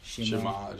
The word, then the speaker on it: Shema: And then in chapter 6, how do you pronounce that word, Shema? – Shema. You'll hear it pronounced different ways by St. Louis. – Right. – Yeah, the Shema is how you see here. I hear most people say it.